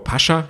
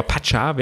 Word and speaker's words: Pacha: Die haben ja so einen ganz, ganz feudalen VIP-Bereich in dem, ich sag ja nach wie vor Pascha, ja, so deutsch, aber Pacha wäre glaube ich, die richtige Aussprache.